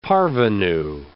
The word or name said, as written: parvenu